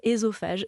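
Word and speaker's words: œsophage: Donc le « e » dans l'eau, quand c'est suivi par une consonne, se prononce « é ». On devrait dire « estrogène »,« ésophage », etc.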